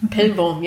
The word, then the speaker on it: Pellworm: Pellworm, ja, ja, ich habe es jetzt richtig betont.